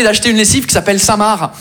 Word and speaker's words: Saint-Marc: On est d'accord, les Français, que Saint-Marc, ça s'écrit M-A-R-C, comme le prénom Marc. Mais vous, vous mettez un saint devant le prénom Marc, le C, il est parti en grève.